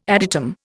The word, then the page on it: adytum: Indian